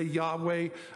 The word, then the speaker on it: Yahweh: Jehovah, some would say Yahweh, and that's just because of the way it's spelled in the Hebrew.